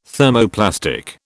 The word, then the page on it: Thermoplastic: British English